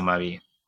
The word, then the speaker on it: maubi: So in Jamaica, which is probably the spelling that you will see a lot if you look it up online, it's spelled M-A-U-B-I. But it's still pronounced the same way. It's still Mavi.